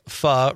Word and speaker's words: pho: Eric, pho rhymes with duh, is a Vietnamese soup, includes rice noodles, shaved meat, other meats, and veggies can go into it.